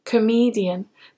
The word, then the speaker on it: comedian: Comedian. Now the word comedian has three syllables and the stress is on the middle syllable.